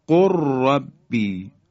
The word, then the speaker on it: قل رب: خب، یا در حرف لام، لام ساکن در حرف را، دقت کنید، قل ربی، قل ربی، لام ساکن در حرف را ادغام میشه، مثل قر ربی، قر ربی. دیگه لام رو ما تلفظ نمی کنیم، بلکه حرف را مشدد بیان میشه.